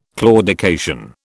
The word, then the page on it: claudication: British English